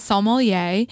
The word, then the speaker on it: sommelier: She is a sommelier. – How do you say it? – Sommelier. – Is that how you say it? Okay.